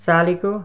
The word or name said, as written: SALIGO